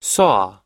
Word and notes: saw: American English

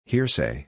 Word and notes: hearsay: ir

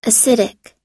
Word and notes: acidic: American English

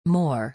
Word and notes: more: or

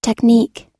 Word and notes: technique: long-i

